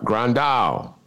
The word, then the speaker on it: Grandal: Grandal.